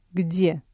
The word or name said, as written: где